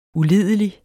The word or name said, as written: ulidelig